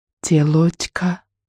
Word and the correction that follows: тёлочка: «Не телочка, а тёлочка», — поправил он.